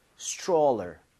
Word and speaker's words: stroller: 읽을 때 여기 tr mom 이렇게 되면 얘가 t하고 r을 충돌을 하면서 치읓 비슷해집니다. 그래서 스트롤러가 아니라 입 모양을 좀 모으면서 stroller 이렇게 해서 발음을 해보는 거예요.